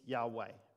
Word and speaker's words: Yahweh: That means God's name, the four Hebrew consonants, Yod, Hey, Vav, Hey, which the best we can pronounce it is Yahweh. Like, that's just trying to add in some vowels and make the most of what we have, but it's four Hebrew letters that we would say Yahweh.